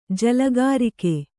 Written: ಜಲಗಾರಿಕೆ

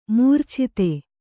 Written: ಮಾರೀಚತೆ

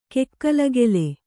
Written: ಕಿಕ್ಕಿಲಿಕಿಲಿ

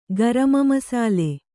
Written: ಗರಮಮಸಾಲೆ

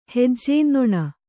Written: ಹೆಜ್ಜೇನ್ನೊಣ

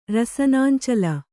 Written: ರಸನಾಂಚಲ

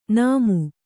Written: ನಾಮು

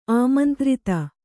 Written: ಆಮಂತ್ರಿತೆ

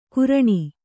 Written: ಕುರ್ಣಿ

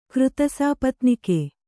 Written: ಕೃತಸಾಪತ್ನಿಕೆ

